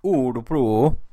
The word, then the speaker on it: Örebro: And Örebro, Örebro, as we would say, in English.